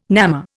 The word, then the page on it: namma: Indian